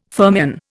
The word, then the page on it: fermion: Indian